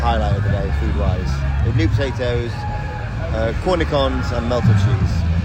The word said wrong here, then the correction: cornichons: Cornicons, is that how you say it? – Cornichons? – Is it cornichons? – Either or, potato, potato.